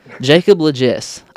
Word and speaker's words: Jacob Lagesse: As you heard, our guest, my guest for today, a good friend of mine, singer, worship leader, and hair enthusiast, Jacob Lagesse.